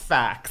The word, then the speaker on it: FAQs: It's called the facts, FAQs. It's pronounced facts.